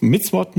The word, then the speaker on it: Mitzvot: Das sind die, ich glaube, Misswort muss, Mitzvot muss man es aussprechen.